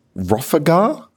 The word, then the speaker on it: Rofagha: Max, how do you say your second name? – They asked me this last time, and I told them try. – Rofagar? – Close, yeah, Rofagar.